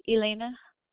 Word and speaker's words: Elaina: It's E-L-E-E-N-A. Is that right? – It's E-L-A-I-N-A, Elena. – Okay, can you repeat it again? Can you spell it one more time, please? – E-L-A-I-N-A. – Thank you so much. It was E-L-A-I-N-A, correct? – Yeah, with an E. E-L-A-I-N-A, like E as an elephant, yeah.